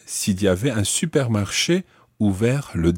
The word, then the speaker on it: ouvert: Ouvert, it's spelled O U V E R T, and that is the masculine form of that word.